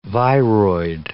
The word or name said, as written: viroid